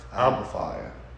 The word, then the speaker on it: Alba Fyre: Alba Fyre? – Alba Fyre.